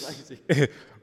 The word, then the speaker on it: Isaac: Wait a minute. Is it Isaac or Isaac? – It's Isaac. I said we was going to call him Isaac, but I'm going to show him some respect. It's Isaac.